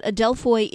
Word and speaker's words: Adelphoi: Well, to Jesus Adelphoi, which is a Greek word, and that's spelled A-D-E-L-P-H-O-I, in case you're curious, Adelphoi.